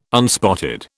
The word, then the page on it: unspotted: British English